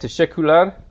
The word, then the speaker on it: teşekkürler: I did understand teşekkürler. Or is that how you say it? Teşekkürler? – Teşekkürler ya da ya da means or teşekkür ederim. So there's two ways to say thank you.